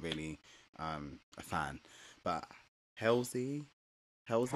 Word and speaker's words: Halsey: Um, so is her name pronounced Halsey? Halsey. – Halsey. – Halsey, okay, Halsey.